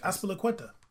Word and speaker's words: Azpilicueta: Azpilicueta. – Azpilicueta? – I feel like he's got to be Basque.